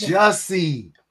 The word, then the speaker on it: Jussie: Juicy Smooliette. – It's Jussie.